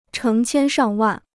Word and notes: 成千上万: chéng qiān shàng wàn

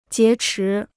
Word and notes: 劫持: jié chí

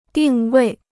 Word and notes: 定位: dìng wèi

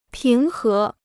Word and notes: 平和: píng hé

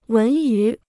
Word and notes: 文娱: wén yú

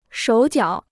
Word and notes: 手脚: shǒu jiǎo

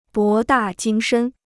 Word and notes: 博大精深: bó dà jīng shēn

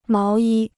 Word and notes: 毛衣: máo yī

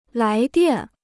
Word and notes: 来电: lái diàn

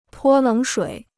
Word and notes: 泼冷水: pō lěng shuǐ

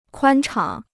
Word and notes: 宽敞: kuān chang

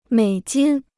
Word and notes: 美金: měi jīn

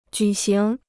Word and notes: 举行: jǔ xíng

